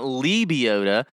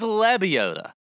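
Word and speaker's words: Lebioda: Okay, 8K range starts with Patton Kizzire, my boy Mav McNeely, Troy Merritt, Lucas Glover, Zach Johnson, Hank Lebiota. If you're from CBS and you're listening to this, it's not Lebiota. It is Lebiota.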